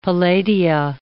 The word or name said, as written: palladia